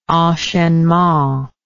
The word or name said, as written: enchainement